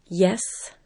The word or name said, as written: yes